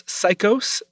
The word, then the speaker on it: Saicos: So that was El Entierro de los Gatos by Los Psychos. That's spelled S-A-I-C-O-S.